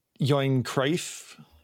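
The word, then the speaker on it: Johan Cruyff: Yoin Cruyff.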